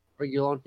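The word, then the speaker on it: Reguilón: Reguilon. Is that right? Reguilon, yeah, from Madrid as well.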